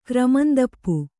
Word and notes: ಕ್ರಮಂದಪ್ಪು: kannada

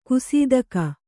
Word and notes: ಗಸಿತೆಗೆ: Kannada

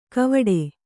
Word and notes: ಗಾವಟೆ: Kannada

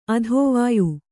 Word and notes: ಅದ್ವಯ: advaya

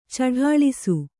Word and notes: ಚಡಾಳಿಸು: caḍāḷisu

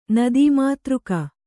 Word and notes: ನದೀಮಾತೃಕ: Kannada

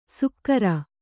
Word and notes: ಸುಕ್ಕರ: sukkara

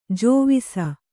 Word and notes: ಜೋವಿಸ: kannada

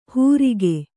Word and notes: ಹೂರಿಗೆ: hūrige; Kannada

